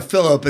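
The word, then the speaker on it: Filip: Philip, I think, is the way they pronounce it in the book. – Yeah. – Philip in the. – Okay. – So it's not that. I know you're a French expert, but it's not French this time.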